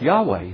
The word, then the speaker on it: Yahweh: Those letters are pronounced Yahweh.